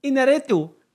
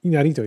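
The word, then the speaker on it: Iñárritu: Inarito. – Inarito, ja. – Inarito.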